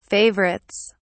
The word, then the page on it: favorites: British English